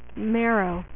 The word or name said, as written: marrow